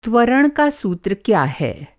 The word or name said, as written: त्वरण